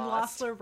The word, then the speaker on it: Lossler: And Lossler rhymes with hostler, so still there, still doing the thing.